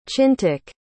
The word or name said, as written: chintak